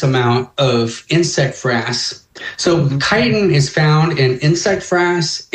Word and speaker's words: chitin: So a friend of mine, and I'm sure It's not chitin. – It looks like chitin, and it's actually pronounced chitin.